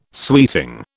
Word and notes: suiting: British English

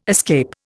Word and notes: escape: Indian

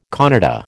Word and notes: Kannada: American English

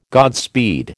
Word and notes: Godspeed: American English

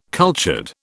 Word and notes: cultured: British English

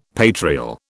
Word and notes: patrial: British English

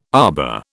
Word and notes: arbour: British English

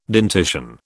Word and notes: dentition: British English

